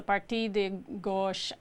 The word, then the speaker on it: Parti de Gauche: So tell us about the left party in France, the Parti de Gauche, and I don't know if I'm pronouncing that right.